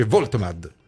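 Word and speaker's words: Woltemade: Gioacchino, si scrive Voltemade, si legge Voitemad.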